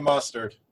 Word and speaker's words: Mostert: Did we ever figure out how to pronounce that guy? – We're sticking with Mostert. – Mustard. Okay. And this is a slate where you can play the kickers.